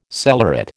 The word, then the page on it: cellaret: American English